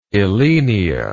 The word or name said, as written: elaenia